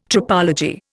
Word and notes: tropology: Indian

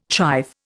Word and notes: chive: Indian

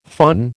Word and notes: faun: American English

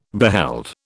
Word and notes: beheld: British English

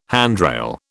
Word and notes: handrail: British English

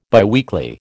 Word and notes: biweekly: American English

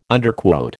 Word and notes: Underquote: American English

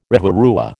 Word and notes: rewarewa: American English

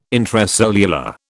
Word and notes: intracellular: British English

